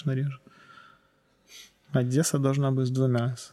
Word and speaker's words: Одесса: То есть Одесса, нужно говорить «Одесса, Одесса».